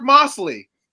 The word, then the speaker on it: Moseley: Moseley, and they're going to be like, who the hell is Mark Moseley? – Really? No, I think it's still pronounced Moseley is how that would go. It would still be Moseley.